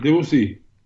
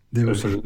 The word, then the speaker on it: Debussy: Sí, Debussy, Debussy, mejor pronunciado.